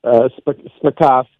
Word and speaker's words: Spakovsky: Spakovsky.